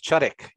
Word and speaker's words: Chudyk: Chudik, yes.